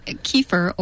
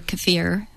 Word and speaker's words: kefir: And kefir, or kefir, which is probably the correct pronunciation, is of Turkish origin.